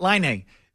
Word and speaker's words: Laine: Laine, right? – Laine.